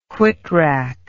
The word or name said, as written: whitrack